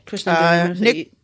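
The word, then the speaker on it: Krishnan Guru-Murthy: Krishnan Gurumurthy, who I hope I've said that right. – Yeah, Krishnan Gurumurthy.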